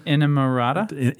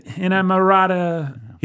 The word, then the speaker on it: inamorata: Inamorata. How do you say that word? – Inamorata. – Inamorata.